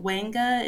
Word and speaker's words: Ouanga: Yeah, so Wenga is spelled O-U-A-N-G-A.